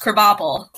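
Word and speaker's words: Krabappel: Right, which to be fair, in The Simpsons, it's pronounced Krabapple.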